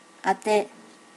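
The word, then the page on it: ate: native Japanese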